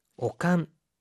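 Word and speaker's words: 悪寒: 오칸. 세기가 되다리 오칸가 する時は 오후로니 하이라나이데 ください. – 자, 오한이라고 할 때 이 악자는요. 오로 읽어서 오칸이라고 합니다.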